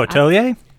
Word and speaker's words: Atelier: And it was inspired by that series that I can't pronounce the name of that you want to try soon is Altier. – Oh, Atelier? that's it.